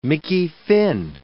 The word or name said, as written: Mickey Finn